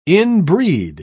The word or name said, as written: inbreed